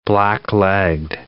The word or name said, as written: black-legged tick